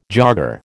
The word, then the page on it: jogger: American English